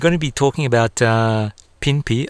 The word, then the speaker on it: Pinpi: I hope I'm pronouncing everything correctly. Pinpi goes to Paris. It's an exhibition of contemporary Australian Indigenous fashion and design creatives. What can you tell us about that? – Oh, thank you. And Pinpi is how you pronounce it. Well done.